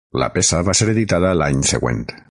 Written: editada

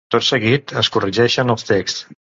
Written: texts

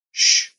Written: sh

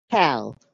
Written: cal

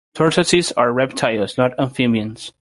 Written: amphibians

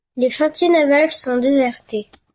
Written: chantiers

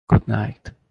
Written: goodnight

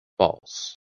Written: FALSE

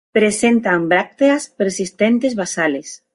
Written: persistentes

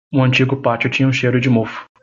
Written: mofo

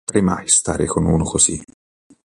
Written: stare

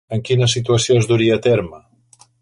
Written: duria

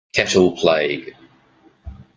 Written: cattle plague

